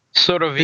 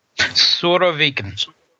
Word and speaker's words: Surovikin: but help correct my pronunciation. Is it Surovikin? – Surovikin. – The general? – Surovikin. – Say it again. – Surovikin. – Surovikin. – Close enough.